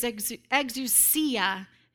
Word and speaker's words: exousia: That word there is exousia. That's how you say it.